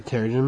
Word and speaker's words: Terrigen: you know that the Trigen Mist is still rolling around the planet. – The Terrigen Mist? – Terrigen Mist.